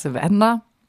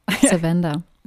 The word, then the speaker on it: Savannah: Savannah. – Savannah.